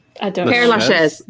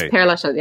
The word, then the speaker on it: Père Lachaise: Is the Pierre Lachaise Cemetery? – Pierre Lachaise. – Am I closed? – Père Lachaise. – Okay. – It's Père Lachaise. Yeah, Father Lachaise. Yeah. – Père Lachaise Cemetery.